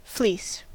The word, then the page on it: fleece: American English